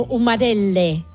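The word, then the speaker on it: umarell: ¿Humarels? ¿Es así? ¿Se dice así? – En el dialecto de Bolonia, de donde procede, es como umarelle.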